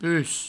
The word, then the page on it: pus: provençal